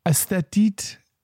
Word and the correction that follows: aesthetic: Like A-S-T-H-E-T-E. – Aesthetic.